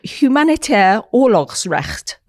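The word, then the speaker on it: humanitair oorlogsrecht: It is a humanitaire oorlogsrecht. That's how it's said in Dutch, which is International Humanitarian Law Congress organized by the Netherlands Red Cross.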